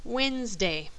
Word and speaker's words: Wednesday: The second vowel is skipped, but the D of the first syllable is also skipped, so it sounds like Wednesday.